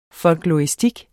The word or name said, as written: FOLKLORISTIK